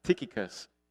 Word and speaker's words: Tychicus: He was accompanied by Sopater, son of Pyrrhus, from Berea, Aristarchus and Secundus from Thessalonica, Gaius from Derbe, Timothy also, and Tychicus. That is the correct pronunciation of this word. I looked it up. Tychicus, my new favorite word.